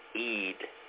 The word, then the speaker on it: Eid: And we know that Eid, and some people say it, so we gotta get out of the habit of saying Eid. It's not Eid. Eid is a different word with a different connotation to it. It's Eid. Just imagine double E, long E in terms of your phonics back in the day.